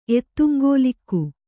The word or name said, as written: ಎತ್ತುಂಗೋಲಿಕ್ಕು